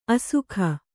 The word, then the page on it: ಆಶಕ: āśaka